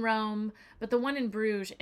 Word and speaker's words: Bruges: But yeah, so we are going to be in Bruges, and the Dutch / Flemish pronounce it Brugge, and the French pronounce it Bruges. And I would say Bruges is the most common pronunciation that most people would hear.